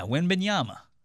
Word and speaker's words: Wembanyama: is getting Victor Wembanyama. Did I say that right? Close enough. Victor. Victor Wembanyama. Yeah, Wembanyama. It's fun to say Wembanyama.